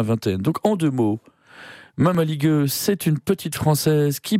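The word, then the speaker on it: Mămăligă: Et dans Mamaliga, pour le prononcer à la roumaine, meumeligueux,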